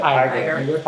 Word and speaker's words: Iger: Iger.